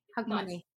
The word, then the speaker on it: Hogmanay: Hogmanay.